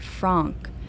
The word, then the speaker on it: Frank: Not Frank, like the American version of hot dog with beans, a dish that Shelby seems rather fond of, but Franck, rhymes with conch, which is what I'd like to do right about now.